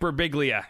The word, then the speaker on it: Birbiglia: I can never say his name correctly. Mike Birbiglia. There you go.